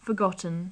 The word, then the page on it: forgotten: English